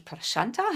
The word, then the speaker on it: Prashanta: Also ich unterrichte im Yoga Prasanta, nennen sie es alle, aber eigentlich würde es Prashanta ausgesprochen, Prashanta, weil es von Shanti, von Frieden kommt.